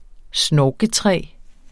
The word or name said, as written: snorketræ